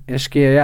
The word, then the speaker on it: Ischia: Yeah, Ischia, I have to say, I loved Ischia.